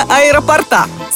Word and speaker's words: аэропорта: В именительном падеже единственного числа ударная буква «о» – аэропорта. Во всех других падежах, за исключением дательного, в аэропорту, ударение также падает на «о». Следовательно, говорить «аэропорта» и ставить ударение на последний слог неправильно.